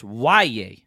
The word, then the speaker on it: Yahya: It's spelled Y-A-H-Y-A, but it's pronounced Y-A.